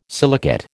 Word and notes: silicate: American English

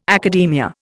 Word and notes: academia: Indian

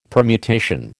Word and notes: permutation: American English

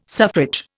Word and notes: suffrage: Indian

